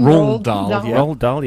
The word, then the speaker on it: Roald Dahl: Roald Dahl, yeah.